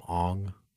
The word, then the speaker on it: Aang: Yeah, his name is Aang, but in the movie they call him Ong.